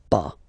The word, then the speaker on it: pas: The first one is ne, and then you get your verb, and right after your verb you will have to put this pas. Okay, so first ne, then the verb, and after that pas. Okay, we write it P-A-S, okay, but as usual, final S is not pronounced, so it's pa.